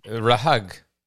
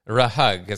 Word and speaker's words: RHUG: I think, they spell it R-H-U-G, Red Hat Users Group. – RUG. The H is silent. RUG. It's like a B-hag, an R-hag, an R-hug.